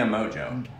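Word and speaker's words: Mongeau: Tana Mojo. Yeah. – Mojo. Is that her name? Is that her name? – That's how you say it. – Okay, well, anyway. – It's not spelled like that at all.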